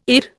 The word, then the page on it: IR: Indian